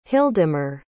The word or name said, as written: hildemar